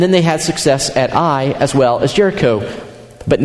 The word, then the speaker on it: Ai: Then they faced off against this other city called, we all call it Ai. And that's okay if you call it that. But the proper pronunciation is simply Ai.